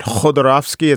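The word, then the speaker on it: Khodorkovsky: Citizen K is the story of Mikhail, I want to pronounce it right, Khodorovsky. It's like the K and the, it sort of sounds like an H when you say it in Russian.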